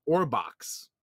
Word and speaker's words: Orbaks: These like horse type things, which are called Orbox. It's spelled O-R-B-A-K-S.